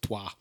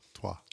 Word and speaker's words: Troyes: It looks like Troyes, but it's pronounced Troyes. – Troyes.